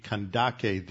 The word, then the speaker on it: Kandake: the Old King James translated the K. There's no sibilant C sound. That's in the like in Candace, you have a hard C at the beginning and then a soft sibilant C like an S at the end. In Greek, you don't have that. You just have the K, which is a hard K, Kandake.